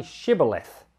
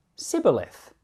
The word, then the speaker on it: Shibboleth: If he said no, they would say to him, "Now say Shibboleth." And he said, "Sibboleth," for he couldn't manage to pronounce it correctly.